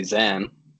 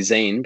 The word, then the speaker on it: Zain: Yeah, so the funny thing about my name, I grew up, my parents would call me Zen. And I always used to correct everyone when they would call me Zain because it's spelled Zain.